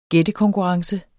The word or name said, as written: gættekonkurrence